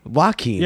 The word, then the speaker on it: Joaquin: How do you say it? – Joaquin? – Joaquin. – Yeah, right? – That's right. That's how everyone should say it, but not me.